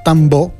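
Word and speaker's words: Tambó: Pubblicato l'album Tambo, scritto proprio Tambo con l'accento sulla O, per Ropa Dope il 15 maggio 2026.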